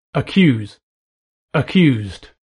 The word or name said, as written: accuse